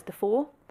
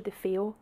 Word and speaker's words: DeFeo: So the whole time I was researching this case, in my head I was reading it as Defoe. And then I started watching documentaries and it's actually DeFeo.